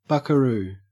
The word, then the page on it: buckaroo: Australian English